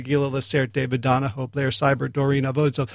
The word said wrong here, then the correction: Avizov: Okay, Doron. You beat me. Doron Avizov rhymes with Keep the Cheese Off.